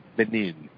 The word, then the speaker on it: Benin: See, she said it right. Benin. Benin. – Yes. – Yeah. We say Benin City. She says Benin.